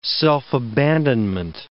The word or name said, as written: self-abandonment